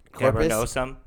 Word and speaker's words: cavernosum: Cavernosum.